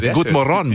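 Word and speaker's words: God morgon: Gut Morgon, muss ich sagen. Okay, Betonung auf der letzten Silbe.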